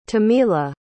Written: Timila

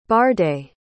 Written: barday

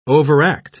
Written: overact

